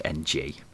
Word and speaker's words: Ng: I believe it's pronounced NG, not Ing. – It's Perry Ing. – It's Perry NG.